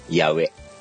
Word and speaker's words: 八重: 八重、八重、ただあの文字で書くと八重なんだけど、発音で書くと八上、八うに小さな絵、やうえ。